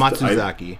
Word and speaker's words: Matsuzaki: but I did not like Satomi Matsuzaki, excuse me. I didn't like her vocals. I don't want to butcher her last name. But I just... – Matsuzaki.